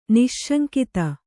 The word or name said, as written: ನಿಸ್ಸಂಗತೆ